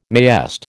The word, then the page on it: mayest: American English